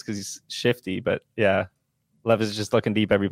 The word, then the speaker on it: Levis: I can't tell if Will Levis, now that we've learned how to say his name, it's not Levis, Levis, is good for Tyjae Spears or not.